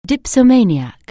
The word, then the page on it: dipsomaniac: anglais